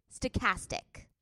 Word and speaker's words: stochastic: Stochastic.